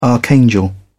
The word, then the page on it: archangel: British English